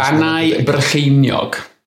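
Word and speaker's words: Bannau Brycheiniog: Banai Brycheiniog. There you go. – Banai Brycheiniog.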